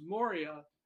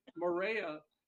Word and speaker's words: Moria: is that if I had known the correct pronunciation of the island, which to the natives there apparently is Moria, I never could have written this song, because the phrasing is such that when you hear it, you'll see that only by my pronunciation, my ignorance, calling it Morea, did it turn out the way it did.